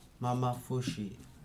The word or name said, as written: mamafuji